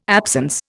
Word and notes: absence: Indian

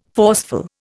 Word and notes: forceful: Indian

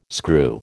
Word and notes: screw: American English

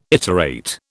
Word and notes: iterate: British English

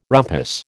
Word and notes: rumpus: American English

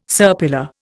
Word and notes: serpula: Indian

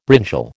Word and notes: brinjal: American English

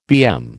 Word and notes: BM: American English

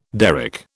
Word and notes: derrick: British English

